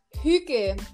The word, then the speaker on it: hygge: Hygge. So it's spelled H-Y-G-G-E. Hygge.